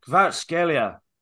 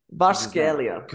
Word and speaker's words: Kvaratskhelia: Kvarat, Kvaratskhelia. – Kvaratskhelia. – Kvaratskhelia.